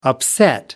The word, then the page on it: upset: American English